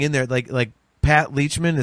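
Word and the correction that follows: Lachman: is that how you pronounce the last name? – Latchman. – Yeah.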